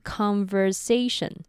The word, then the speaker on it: conversation: 接下来是 conversation，这是一个比较长的单字，注意第一个母音 o 在这里是 r 的发音， t i o n 则要念成 shen。